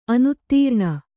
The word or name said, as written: ಅನುತ್ತೀರ್ಣ